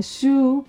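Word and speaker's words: shu (酒): Y el segundo kanji es el kanji que se pronuncia shu, pero también se pronuncia como sake y es bebida alcohólica, básicamente.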